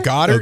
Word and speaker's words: Goedert: We're in the post game in the tunnel with Dallas Goedert. Am I saying his name right? – Goddard. Dallas Goddard.